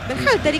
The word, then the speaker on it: Berhalter: Dígalo usted. – Berhalter. – ¿Y qué dije yo? – No, impecable.